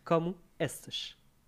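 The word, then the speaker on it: Como estás: C'est comment qu'il faut le... – « Como estas? » »« Como estas? » Comme moustache.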